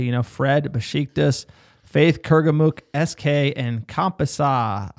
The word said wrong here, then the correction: Kasımpaşa: I don't even know. Kasımpaşa, sir.